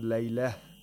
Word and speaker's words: لَيْلَة: If you stop on laylah, the ta, which is ta marbuta, becomes a ha, is pronounced like a ha. But if you don't stop, if you continue, the ta is pronounced like a normal ta.